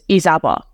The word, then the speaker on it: Izaba: My authentic Afghan pronunciation of my name is Izaba, which is, yeah.